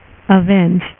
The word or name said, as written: avengers